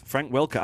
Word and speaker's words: Frank Welker: Frank Walker. It says Welker here. – It's spelled Welker. Frank Welker. I'm wrong. It's Welker.